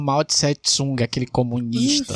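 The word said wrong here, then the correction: Mao Tsé-Tung: É, tem a mesma pronúncia lá, do jeito que a gente fala aqui, Mao Tse-Tsung? Ou então tem... – Mao Tse-Tung.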